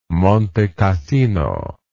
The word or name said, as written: Monte Cassino